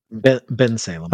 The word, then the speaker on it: Bensalem: That's in Pensilom. Is that how you say it? – Bensalem.